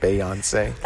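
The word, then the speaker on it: Beyoncé: Is that how you pronounce it? Beyonce? – That's how you pronounce it.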